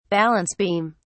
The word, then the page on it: balance beam: British English